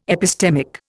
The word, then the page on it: epistemic: Indian